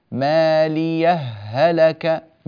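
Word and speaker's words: ماليه هلك: السكت يعني من غير تنفس دون زمن الوقف، هكذا: مالية هلك عني، مالية هلك عني.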